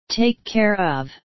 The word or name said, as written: take care of